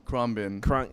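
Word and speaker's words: Khruangbin: It's K-H-R-U-A-N-G. – How do you spell it? Or how do you say it? – Krumbin. – Wow. Yeah, Krumbin.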